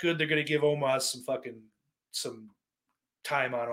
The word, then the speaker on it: Omos: Omos, I think is how you say it. I think I finally figured that out.